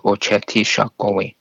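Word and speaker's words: Očhéthi Šakówiŋ: They recognize each other as Ochetishakowi, which is the Seven Council Fires, and that's how it's pronounced, Ochetishakowi.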